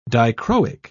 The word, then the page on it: dichroic: British English